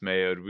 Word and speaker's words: Maod: The guard's name is spelled M-A-O-D. – Why would you do this to me? So isn't it Mayode? – There you go. We all know it's pronounced Mayode.